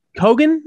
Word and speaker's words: Keoghan: I can never pronounce his last name. Keoghan? – Keoghan is what I've been saying.